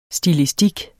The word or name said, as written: stilistik